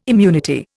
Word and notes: immunity: Indian

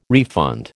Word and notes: refund: American English

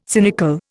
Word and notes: cynical: Indian

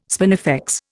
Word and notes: spinifex: Indian

